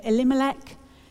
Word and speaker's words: Elimelech: So I think it's because one of the characters has a four-syllable name and we place the emphasis on the second syllable. And what that means is every time I hear the name Elimelech, in my head I'm singing that to the tune of A Whim Away.